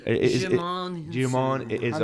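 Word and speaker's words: Djimon Hounsou: Dijimu Hunsu? – Oh, yeah. – Is that how you say his name? – Jimon Hunsu.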